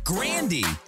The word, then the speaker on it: Grande: I think one of the biggest shockers of 2018 was that we've been mispronouncing Ariana Grande's name this entire time. It's actually Grandy.